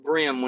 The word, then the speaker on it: bream: It's pronounced brim when it's spelled bream.